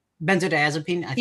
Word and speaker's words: benzodiazepine: Benzodiazepine.